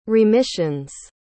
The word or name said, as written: remissions